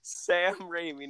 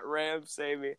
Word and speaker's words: Raimi: I was like, I know I'm saying that wrong. Sam Raimi, not Ramsamy.